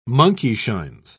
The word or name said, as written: monkeyshines